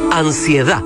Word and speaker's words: Ansiedad: Del pop de Taylor al sonido nacional nos acompaña Jepe y este tema que se llama Ansiedad, así, sin de final y con acento en la última A. Ansiedad, a última hora, Cooperativa.